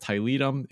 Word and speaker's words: Tiletum: And I watched the how to play video from Gaming Rules, and Gaming Rules started right out by saying, apparently you can pronounce it either way, pronounce it however you want to. So for the rest of this discussion, we're going to go with Tiletum. It is no longer pronounced Tiletum. I'm glad to hear that. It is forever pronounced Tiletum in my vocabulary.